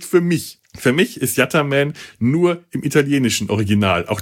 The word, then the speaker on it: Yattaman: Yattaman schreibt sich in Italien Yattaman, wird auch eher Yattaman ausgesprochen. In allen anderen Versionen ist es Yatter mit ER, komisch.